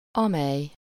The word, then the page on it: amely: /ˈɒmɛj/